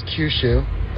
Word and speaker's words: Kyushu: The only one you guys missed was Kyushu. That's how it's pronounced.